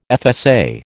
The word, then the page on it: FSA: American English